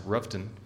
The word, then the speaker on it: Rutherfordton: If you're from around that area, you know that it's correctly pronounced Rufton.